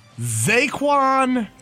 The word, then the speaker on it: Zxaequan: going to the University of Missouri, Zaquan Reeves. Doesn't sound unusual. – Doesn't, no. – But here's the spelling of his first name. Z-X-A-E-Q-U-A-N. – How do you spell it? – Z-X-A-E-Q-U-A-N. – Zaquan Reeves.